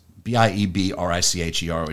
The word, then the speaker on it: Biebricher: Nick is, and I'm hoping to pronounce his name right, it's Biebricher, it's Scottish, but he just goes by Biebry because nobody can pronounce it, so that's what he calls it.